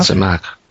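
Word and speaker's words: Tzemach: Other prophets reveal Mashiach as the branch, but use the Hebrew, now how would I pronounce that? Zimach? – Zimach, uh-huh. Yes.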